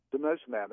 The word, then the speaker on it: denosumab: how do I say that? Denosumab? – Denosumab, that's correct.